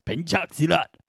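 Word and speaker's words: Pencak Silat: Just this Penchak Silat, I believe that's how it's pronounced, Penchak Silat, martial art of just kicking the absolute shit out of a bunch of guys.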